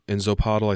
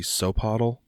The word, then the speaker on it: Xopatl: It's technically, I think I'm kind of doing the Z a little hard in Zapotl. I think it's supposed to be Sopotl, but it starts with, it's spelled X-O-P-A-T-L.